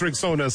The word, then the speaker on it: Rexona: It is pronounced Rexona, simple, strong, and easy to remember.